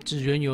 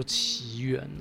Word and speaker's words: 祇園: 嗯，纸鸢有人认为应该要念作奇缘呢，不过我这边还是按照京都市巴士和金板电车中文广播的念法哦，念作纸鸢呐，请这个奇缘派的人忍耐一下嘿。